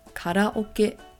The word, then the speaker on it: karaoke: In Japanese, we pronounce this as karaoke.